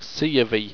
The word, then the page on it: seyovy: owstwecian